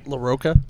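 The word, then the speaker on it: Larroca: How do you say that? – LaRocca. – LaRocca? – Yeah.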